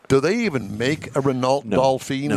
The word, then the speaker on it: Dauphine: But it's a Dauphine. It's pronounced Dauphine. – I know, but I like Dauphine. – Dauphine.